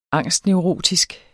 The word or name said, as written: angstneurotisk